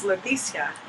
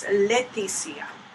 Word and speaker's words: Leticia: So actually it's Leticia. And a lot of people say Leticia or Leticia, but it's Leticia. – Emphasis on the let.